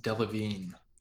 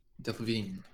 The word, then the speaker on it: Delevingne: Her last name is pronounced Delevingne. – Delevingne. – Delevingne.